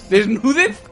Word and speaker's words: Desnudez: ¿Desnudez? Como el agente desnudez. – Desnudez. – No desnudez, desnudez. – Con acento en la U.